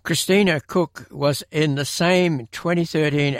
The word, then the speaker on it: Koch: Cook, whose name is spelled K-O-C-H, holds a Bachelor of Science degree in Electrical Engineering and Physics and a Master of Science degree in Electrical Engineering.